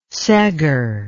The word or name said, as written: seggar